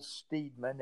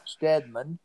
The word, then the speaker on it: Steedman: Well, the USCT was under the command of General James, it's spelled Steedman, S-T-E-E-D-M-A-N. Of course, I wasn't alive and didn't know him. Some people call him Steadman.